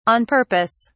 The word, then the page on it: on purpose: British English